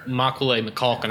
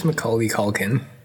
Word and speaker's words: Macaulay Culkin: I think we're both saying it wrong. I don't know. – I'm on Macaulay Culkin's Wikipedia page, and they don't have a pronunciation of his name, which really upsets me. – I think it's Macaulay Culkin. – Macaulay. It's Macaulay, which is far too many vowels.